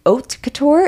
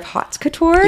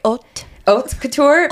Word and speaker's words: haute couture: Also, I think it's pronounced haute couture instead of hot couture. – You are so right. Haute couture.